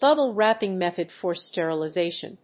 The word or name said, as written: double wrapping method for sterilization